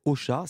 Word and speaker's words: Ausha: Vous avez développé votre offre podcast, je reviens au son, avec Ocha. C'est quoi Ocha ? Ça s'écrit A-U-C-H-A. – A-U-S-H-A. – Et non, S-H-A, pardon, et non pas comme je donne à manger Ocha.